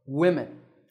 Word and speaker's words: women: In the word women, there are two syllables because there are two groups of sounds, wim and im. If a word has two or more syllables, one syllable will always be stressed. That is, it will be given more importance than the others. In this word, notice that we stress the first syllable, women.